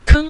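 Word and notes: keng: tone 5

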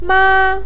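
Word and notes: ma: tone 1

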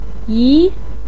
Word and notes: yi: 2

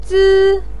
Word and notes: zi: 1

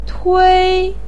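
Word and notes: tui: tone 1

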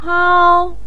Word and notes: hao: 1

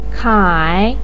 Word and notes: kai: 3

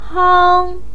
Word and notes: hang: 1

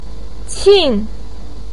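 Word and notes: qin4: tone 4

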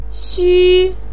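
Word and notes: xu: tone 1

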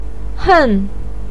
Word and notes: hen: 4

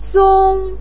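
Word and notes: song: tone 1

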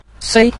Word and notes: sui: sui5

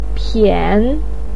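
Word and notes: pian3: tone 3

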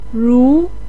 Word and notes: ru: 2

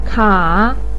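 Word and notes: ka: tone 3

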